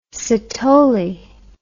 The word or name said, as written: Sithole